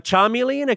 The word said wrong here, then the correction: chameleon: a chameleon. – Chameleon. – Chameleon. Okay. Sorry. The weird spelling.